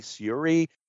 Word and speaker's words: Syuri: Suri, am I pronouncing her name correctly, Jeff? – I'll take your word for it. – Okay, S-Y-U-R-I, Suri.